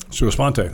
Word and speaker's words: sua sponte: Um, next one, sua sponte. Am I saying that right? Sua sponte. That's right.